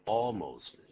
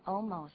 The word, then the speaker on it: almost: 对，所以舌尖要抵上去，almost。因为如果你不把 L 养成习惯，舌尖不抵上去，念成 almost，这个发音就已经不对了，对不对？ – 很多人念成 almost 那样不太对。